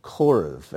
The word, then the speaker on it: Horeb: In fact, he's going to lead the Israelites to Mount Horeb, or Korev, as it's pronounced in Hebrew.